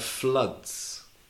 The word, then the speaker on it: flood: It's the same pronunciation, which is blood for your body and flood for when it rains a lot.